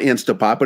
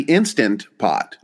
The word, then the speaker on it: Instant Pot: I call it Instapot, but it's actually Instant Pot. – Instant Pot. But when you say fast, you just lose the sound.